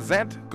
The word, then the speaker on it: Zedd: Se trata de lo nuevo de Ariana Grande, en compañía de un chico llamado Zedd, se pronuncia Zedd con Z, Z-E-D-D, Zedd, quien es ruso-alemán de 24 años de edad, músico, productor, disc jockey, nacido en Saratov, Rusia, y criado en Kaiserslautern, Alemania.